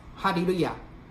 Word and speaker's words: 哈利路亚: 先把那个第一大段那个四个哈利路亚稍微做一个简单的分析。如果以哈利路亚为主体，那是赞美神嘛哈利路亚，那本来是希伯来音哈利路亚就是赞美耶和华赞美真神哈利路亚本来是希伯来音，但是他用希腊文发音就是哈利路亚我们看第一节，